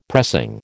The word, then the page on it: pressing: American English